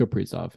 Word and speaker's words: Kaprizov: Is that how I say his name? – I think it's Kaprizov, yeah.